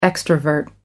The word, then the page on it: extrovert: American English